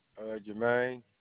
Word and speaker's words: Jermaine: Jermaine, J-E-R-M-A-I-N-E. – J-E-R-M-A-I-N-E? – Yes. – Your last name? – Thomas. – And it's T-H-O-M-S? – Yes.